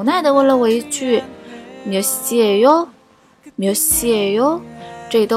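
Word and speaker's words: 몇시예요: 今天我们要学的一句是 몇 시 에 요， 몇 시 에 요，几点了的意思。这里的 예 在口语上可以读成 a a， 몇 시 에 요。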